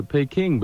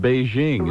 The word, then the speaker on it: Beijing: You know, we used to pronounce it to Beijing, but in the last few years they've asked us to pronounce it Beijing.